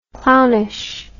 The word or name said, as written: clownishness